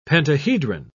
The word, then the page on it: pentahedron: British English